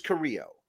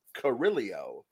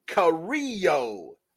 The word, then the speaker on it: Carrillo: Somebody told him his name is Carrillo. And either he is insisting upon pronouncing it that way, Carrillo, or he just can't get it into his head. – No. – That when he looks at a guy's name on the paper, like, can you write it out for him phonetically? C-A-R-E-E-Y-O. Carrillo.